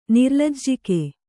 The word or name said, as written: ನಿರ್ಲಜ್ಜಕ